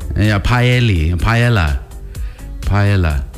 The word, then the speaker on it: paella: P-A-L. – Yeah. – P-A-E-L-L-A. – P-A-E, paella. It's yeah. Paella. That's definitely the way you say it, paella.